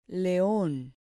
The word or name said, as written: leon